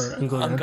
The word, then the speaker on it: Ungoliant: Ungoliant or Ungoliante? Ungoliant, okay. We would say Ungoliante. But Ungoliant would do it too.